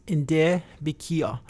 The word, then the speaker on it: Nde Bikia: And the proper way to say the People's Farm in Apache is Nde Bikia.